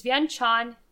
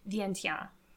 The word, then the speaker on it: Vientiane: Actually, so it's, it's Vientiane in Lao, but then when the French take over, they use a French, a French pronunciation, which is how we see it on American maps today, which is Vientiane.